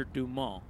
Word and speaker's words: Dumas: In fact, I'm sure the last two are pronounced Sean Hooker and Alexander Dumas.